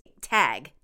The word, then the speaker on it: Tague: My struggle currently is that our last name is Tague, T-A-G-U-E. It's pronounced like tag, and I feel that limits our choices.